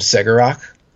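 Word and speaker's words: Cegorach: So we're going to, for our intents and purposes, call him Segarak. It's the way it's pronounced, the way it's spelt is open to multiple different pronunciations, but this is going to be the Foxtrot battle line one.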